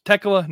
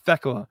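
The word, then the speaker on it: Thekla: And yes, there's an H in the name, but it's silent. If you listen to the commentary, they pronounced it Tekla last night. It's Tekla, not Fekla.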